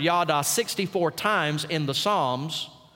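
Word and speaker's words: yada (יָדָה): It's the Hebrew word yada. We would spell it Y-A-D-A. Not Yoda, yada.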